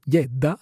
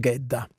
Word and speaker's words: Gedda: Presto abbandonato dai genitori, è adottato dalla zia Olga, dalla quale prenderà il cognome Gedda, secondo la dizione svedese, ma siamo ormai abituati alla pronuncia germanica Gedda.